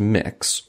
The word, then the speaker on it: Mx: I have recently learned that MX, pronounced mix,